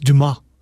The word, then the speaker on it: Dumas: I will say it's Dumas. It's not Dumas. I mean, the S is silent in the French when you...